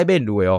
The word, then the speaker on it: Benz: 哇塞，那个车子我们都讲，哦，你这边路诶，上下学哦，哦，你到塞边路诶哦，呃，各位啊，我们现在讲边路是指指宾士嘛，Benz 嘛，哦，就是你用比较台式的发音就边路嘛，哦，可是，在我们那个年代哈，边路是泛称 BMW 跟宾士。